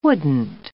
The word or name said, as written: wouldn't